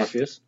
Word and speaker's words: Orpheus: Oh. Without the M. Or okay. Spelled spelled like Morpheus without yeah. the M. Orpheus.